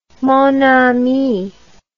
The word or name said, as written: mon ami